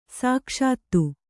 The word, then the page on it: ಸೊಗಸಿತು: Kannada